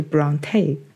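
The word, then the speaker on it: Brontë: 发音就是 Bronte。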